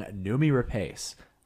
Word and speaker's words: Noomi Rapace: And we swapped films, and Phil is going to go first with my choice, Close, starring Noomi Rapace. I hope I'm saying that right.